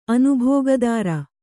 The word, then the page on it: ಅನುಭೋಗದಾರ: anubhōgadāra